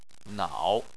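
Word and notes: nao: tone 3

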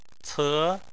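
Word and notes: ce: tone 2

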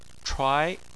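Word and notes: chuai2: tone 2

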